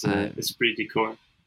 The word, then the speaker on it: esprit de corps: The third word is C-O-R-P-S. Lots of extra letters here that do not get pronounced.